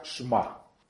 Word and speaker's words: Shema: Nosotros en, la teología cristiana aprendemos a decirle la Shema, se pronuncia Shema.